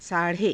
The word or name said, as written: saa_rhe